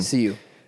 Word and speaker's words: Sioux: Sioux. – The Sioux tribe. – X is silent.